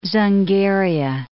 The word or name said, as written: Dzungaria